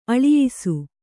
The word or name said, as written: ಆಲಯ್ಸು